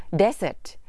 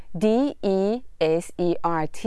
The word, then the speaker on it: desert: สวัสดีค่ะวันนี้ท่านผู้ชมก็มีโอกาสได้รู้จักกับสุนัขจิ้งจอกที่ตัวเล็กที่สุดในโลกแห่งทะเลทรายซาฮารานะคะขอให้สังเกตการออกเสียงคำว่าทะเลทรายในภาษาอังกฤษนะคะ desert d e s e r t นะคะเราจะเน้นที่พยางค์แรกค่ะ desert ขอให้จำนะคะว่าอย่าไปสับสนกับคำว่าขนมหวานนะคะภาษาอังกฤษของคำว่าขนมหวานจะสะกดด้วยตัว s 2 ตัว d e s s e r t dessert คำนี้จะเน้นที่พยางค์ที่สองนะ